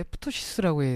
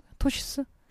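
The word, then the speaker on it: ptosis: 프토시스라고 해야 돼? 읽어야 돼요? 토시스라고 읽어야 돼요? 토시스? 피가 묵음인가? 모르겠어요.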